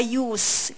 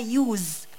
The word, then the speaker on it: use: Use of Force، كلمة Excessive بنكتبها E-X-C-E-S-S-I-V-E. Use هنا بمعنى استخدام، كلمة تعتبر noun أو اسم، عشان كده بننطقها Use، في صه sound مش بقولها Use.